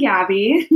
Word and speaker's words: Gabi: Hi, I'm Gabi as well.